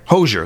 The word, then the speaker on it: Hozier: That song, Too Sweet, from Hozier, or however you say his name. I always mispronounce. Hozier. Thank you, Hozier.